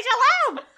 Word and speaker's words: Shalhoub: I just didn't know how to pronounce Tony Shalhoub.